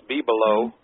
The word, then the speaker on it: bibelots: Well, I look up this, it's spelled as B-I-B-E-L-O-T-S, and it's Bibelots. The S makes the plural Bibelots, and I thought, now what is this?